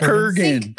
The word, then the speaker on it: Kurgan: that the Krugan, is that how you pronounce Kurgan. – It? – Kurgan.